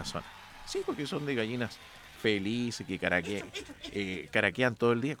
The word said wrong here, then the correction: cacarean: Caraquean, ¿no? ¿O no? Cacarean. Ahí está. Cacarean.